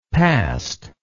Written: passed

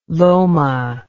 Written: loma

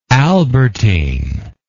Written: albertine

